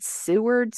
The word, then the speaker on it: Seward: And then Seward, I hope I'm saying that right. Seward. – Sounds about right.